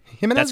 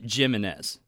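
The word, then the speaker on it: Jiménez: Did I pronounce that right? Jimenez? – That's correct. Yeah, well, he pronounces it Jimenez.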